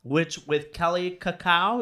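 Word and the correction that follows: Kaley Cuoco: Kaylee Cuoco.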